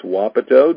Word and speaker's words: Wapato: Spelled the same, C-O-W-I-N, and it's Wapato. That's an Indian name. It's Wapato, W-A-P-A-T-O, and it's Washington. 9-8-9-5-1.